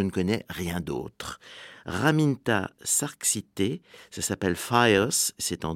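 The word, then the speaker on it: Šerkšnytė: et Serxite c'est le nom. Alors ça s'écrit S avec un petit V comme en tchèque, E-R-K-S, à nouveau un petit V, Y-T-E, et il y a un point sur le E.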